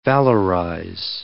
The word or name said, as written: valorize